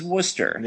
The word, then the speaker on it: Worcester: And it might be spelled Worcester, but it's Worcester.